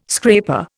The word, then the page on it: scraper: Indian